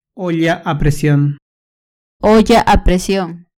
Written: olla a presion

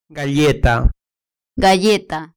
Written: galleta

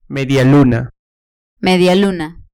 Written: medialuna

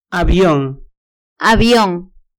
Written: avion

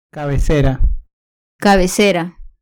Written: cabecera

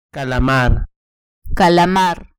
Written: calamar

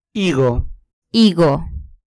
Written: higo

